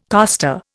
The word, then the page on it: coster: Indian